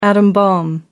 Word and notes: atom bomb: American English

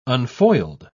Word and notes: unfoiled: British English

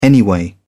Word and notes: anyway: British English

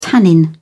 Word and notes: tannin: British English

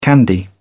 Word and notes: candy: British English

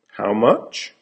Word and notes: how much: American English

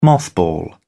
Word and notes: mothball: British English

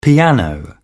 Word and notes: piano: British English

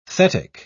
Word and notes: thetic: British English

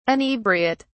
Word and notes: inebriate: British English

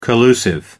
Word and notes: collusive: American English

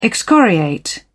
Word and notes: excoriate: British English

